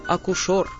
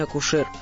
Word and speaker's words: акушер: Вот, например, слово «акушер». – Что-что? – Именно так старые радиодикторы произносили теперешний акушер. В современном словаре для работников радио и телевидения вы такого варианта не найдете.